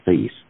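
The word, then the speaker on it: Fils: F-I-L-S, I think it's pronounced Feast.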